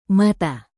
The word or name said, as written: mata